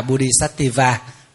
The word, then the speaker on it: Bodhisattva: Bồ Tát tiếng Phạn đọc là Bodhisattva.